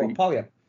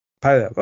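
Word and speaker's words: Puyallup: How would you say that one? Pile up? – Oh, Pile up. – Pile up. Okay, I was kind of close.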